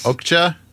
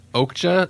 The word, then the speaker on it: Okja: Okja. – Okja, and I think that's how they were saying it in the movie.